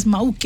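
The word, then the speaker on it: Mauke: Moke is an island there, and they pronounce it as Moke, ne? So like Moke, nice.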